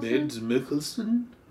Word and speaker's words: Mads Mikkelsen: Mads Mikkelsen? Yeah. I don't know, it's what, Swedish? No, it's... – Danish, I think? – Danish, yes. – Yeah. – So Mads, let's call him Mads. Yeah. Mads Mikkelsen?